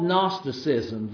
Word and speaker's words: Gnosticism: And that was called Gnosticism. The G in that word is silent. And it comes from the Greek word, it would be spelled in English letters, G-N-O-S-I-S, which would be Gnosis.